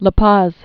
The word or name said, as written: La Paz